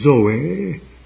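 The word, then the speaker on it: Zoe (ζωή): it is the word Zoe, Z-O-E, with a heavy accent on the last syllable.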